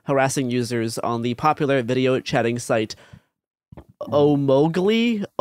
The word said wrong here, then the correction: Omegle: I never know how to say this one. Omogli. – I've never heard of it. I have no help for you here. – People will know what I'm talking about. It's this randomized chatting app that does video chatting with the randomized users. It's Omegle.